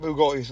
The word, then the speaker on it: Bugatti: Wait, how are we going to get Bugattis, though? – If we scam Andrew Tate of his money in Bugattis... – You're not saying it right. It's Bugatti.